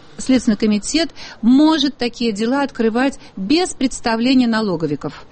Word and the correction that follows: налоговиков: Или налоговиков? – Лучше налоговиков. – Да. Извините, пожалуйста. Да, это профессиональное ударение.